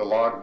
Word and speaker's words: Lag: Now, the reason that the log Ba'omer is, what happened is... – That log? – Log. – L-A-G? – It's either, it's spelled L-O-G and L-A-G because it's a transliteration instead of a translation.